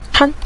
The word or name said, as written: chan5